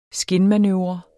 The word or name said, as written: skinmanøvre